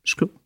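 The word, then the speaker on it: szkło: Un autre studio, donc pareil, je suis désolée, je ne sais pas comment on prononce ça, je vais dire Chclos. – Schkl. – Ça veut dire le verre en polonais. – Ça s'écrit comment? – Ça s'écrit S-Z-K-L-O. Mais je crois que c'est Schkl. Ouais, ça... S-Z-K-L-O.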